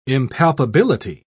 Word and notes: impalpability: British English

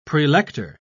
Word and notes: prelector: British English

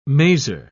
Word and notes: mazer: British English

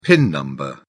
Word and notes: pin number: British English

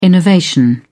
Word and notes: innovation: British English

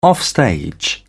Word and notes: offstage: British English

